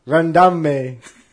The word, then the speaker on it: Randamie: R-A-N-D-A-M-I-E. Randame. – Jermande. Jermande. – Harambe. I was pronouncing like Harambe.